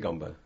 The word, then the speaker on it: Negombo: Negombo. – How do you spell that? N-G, is it? – E-G. N-E-G. – N-E-G.